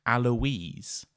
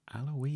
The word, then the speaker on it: Alois: It's his older brother, Alois. – Alois. – I know, I had to look up how to say this because it's spelt A-L-O-I-S.